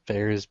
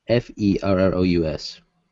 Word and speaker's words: Ferris: I think there's a slight pronunciation difference between Ferris and Ferrus. – Ferris. Ferris. – Ferrous. – Ferris. – As in having similar or the qualities of iron.